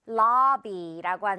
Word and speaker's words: lobby: 자, 그런데 영어에서는 이 O가요. 아 발음 납니다. 그 맛있는 떡 발음 있죠? 입 크게 벌려야 되는 발음. 그래서 lobby라고 하는 거죠.